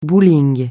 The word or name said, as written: bowling